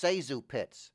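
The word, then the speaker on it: ZaSu Pitts: I do Joe Penner impressions and make quips about Zazu Pitts. – Sazu. – What? – Her name is pronounced Sazu Pitts.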